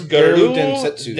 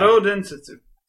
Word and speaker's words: Garou Densetsu: Oh, it's Garou Densetsu. Densetsu in Japan. – Garou Densetsu.